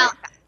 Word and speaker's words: Malachi: And uh, it's that's how I say it, right? Malachi, did I say it right? Malachi. Yeah. yeah. yeah.